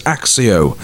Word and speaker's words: Accio: We believe it's pronounced as Accio, okay? A-C-C-I-O, Accio. But it says in the UK audiobook, they pronounce it as Axio. So it depends. It sounds like a Spanish, where you pronounce the C as a K, or it could be as an S.